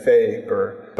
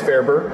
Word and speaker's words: Ferber: But how you say it is Ferber. Most of the time people get caught up on the E and they keep thinking to say Ferber, but it's Ferber,